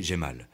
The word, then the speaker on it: Jamal: à Jamal, prononcé Jemal.